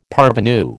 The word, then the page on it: parvenu: American English